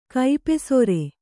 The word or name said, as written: ಕೈಪೆಸರ